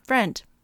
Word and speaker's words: Freund: I have met a couple, and they pronounce it uh, Friend.